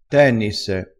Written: tennisse

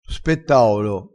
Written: spettaolo

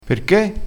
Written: granata

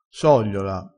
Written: sogliola

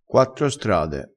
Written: quattro strade

